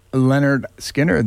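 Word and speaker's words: Lynyrd Skynyrd: Leonard Skinner Band from Pronounced Leonard Skinner, their first record, produced by the great Al Kooper.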